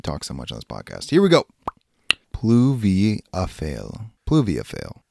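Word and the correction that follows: pluviophile: I pronounced that with an accent. Pluviafile.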